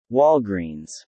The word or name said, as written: Walgreens